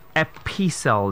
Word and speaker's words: epistle: Epistle, not epistle. No, an epistle of that. E-P-I-S-T-L-E. Epistle. Okay? The t is silent now. Okay, it's not epistle. Alright, just the way you'll pronounce the word apostle.